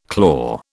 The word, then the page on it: claw: British English